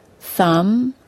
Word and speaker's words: thumb: If a word ends in the letters MB, the B is silent. Let's look at some examples. I already told you the word climb. Some others are thumb, comb, crumb, lamb, limb, bomb, and dumb.